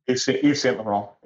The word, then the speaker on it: Yves Saint Laurent: And when he sold his 25% of, I think it was more than that, of Duty Free to Yves Saint Laurent. Am I pronouncing it right? – Yves Saint Laurent, yeah.